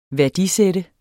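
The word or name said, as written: værdisætte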